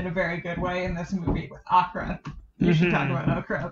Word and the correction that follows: Aughra: It's Agra. A-U-G-H-R-A. – Yeah, it's not Okra.